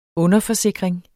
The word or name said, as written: underforsikring